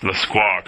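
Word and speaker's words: Lescroart: And it's pronounced LeSquaw, correct? – Perfect.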